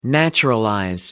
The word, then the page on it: naturalize: American English